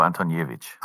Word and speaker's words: Antoniewicz: Ja, Heiko Antoniewicz, ähm, habe ich das richtig ausgesprochen? Er sagt Heiko Antoniewicz. Antoniewicz.